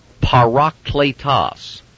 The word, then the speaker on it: parakletos: It is parakletos, or if you prefer, paraclete in the anglicized version.